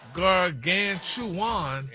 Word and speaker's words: gargantuan: Oh, it sounds like you missed a syllable there, sir. – Oh, gargantuan. – No. No, sir. – Maybe you should just make some more videos with you with different clothes on with Stephanie Mills music, like you've been doing. – Look at the breakdown of the word. – It's pronounced gargantuan.